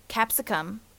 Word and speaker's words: capsicum: Capsicum, I think, is how you pronounce it. C-A-P-S-I-C-U-M, capsicum.